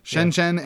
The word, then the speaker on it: Shenzhen: Shenzhen and Nanjing.